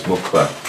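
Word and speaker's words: muqw'lha: Mukukladim, they have, or somebody has mukla. – Mukla or mukla? – Mukla. – Mukla. – Mukla. No, no. Mukla. It's QW apostrophe. Mukla. See, everyone, we grew up saying it wrong.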